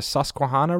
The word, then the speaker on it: Susquehanna: We drove up, is it the Susquehanna River? I don't know how you pronounce it. – That sounds right. That's how to pronounce it.